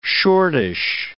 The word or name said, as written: shortish